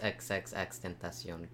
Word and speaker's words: XXXTentacion: Oh, XXXTentacion. Yeah. – You know what hurts? I thought his name was Extension. – No, it's XXXTentacion. Temptation.